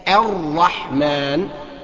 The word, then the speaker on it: الرحمن: الرحمن، وتفخيم الراء بعد منه على طول ارقق الحاء، اقول الرح الرح، ما اقولش الرح، لا، الرحمن، برضو الميم والنون مفخمة مرققة، قصدي يعني مخففة ونحيفة.